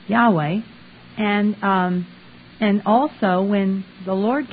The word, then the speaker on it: Yahweh: they put in three syllables and made it Jehovah, and the two-syllable people were the Yahweh people. Well, since the Hebrew had no vowels, I'm going to stick with Yahweh. It's closest. It's only two syllables.